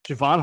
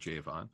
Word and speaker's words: Javon: So Javon Hargrave, he pronounces it Javon. – Oh, is it Javon? Okay. – Yes. It looks like it's spelled Javon, but he calls himself Javon. I've heard him speak. He said his name. So I'm like, all right, you would know Javon, that you were the guy.